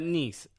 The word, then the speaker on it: Nice: He's at Nice. Okay. – Which is spelled nice.